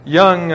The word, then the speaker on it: Jung: That's, it's German, it's Austrian, it's J-U-N-G, but it's pronounced Jung as with a Y.